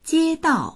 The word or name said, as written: jie1dao4